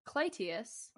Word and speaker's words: Clytius: who we will note is pronounced Clytius.